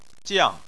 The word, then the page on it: jiang4: chinese